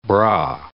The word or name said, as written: bra